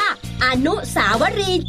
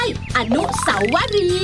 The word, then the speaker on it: อนุสาวรีย์: อ่านว่าอนุสาวรีจ้ะไม่ใช่อนุสาวรีเพื่อนที่เก่งหลายคนเขามักออกเสียงผิดแบบนี่แหละ